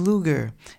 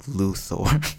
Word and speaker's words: Luthor: And then he starts doing good, and he's like, Lex Luger. And then Lex Luthor's like, Luthor. Luthor. I own the news stations, so please just say it right.